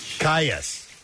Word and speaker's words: Kyuss: Kayas.